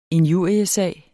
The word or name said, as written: injuriesag